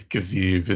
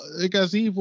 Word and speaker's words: Gaziev: I haven't decided how I'm pronouncing it. – I think it's Gaziev is how they're... – That's what I've heard.